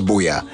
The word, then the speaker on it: Buea: I kept saying that it's pronounced Boya, and it's spelled B-U-E-A.